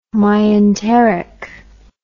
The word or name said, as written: myenteric